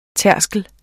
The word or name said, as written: tærskel